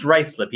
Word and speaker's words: Ruislip: It's pronounced Ruislip, even though by looking at it on the paper, it looks like Ruislip.